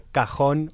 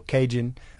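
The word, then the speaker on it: cajón: Um um basically it's called a cajon or cajun as people who can't pronounce say.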